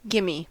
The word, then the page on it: gimme: American English